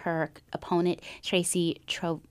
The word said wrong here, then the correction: Trojowski: I'm going to just keep saying her name wrong. – Trojowski. – Thank you. Can you say it for me? – Tracy Trojowski.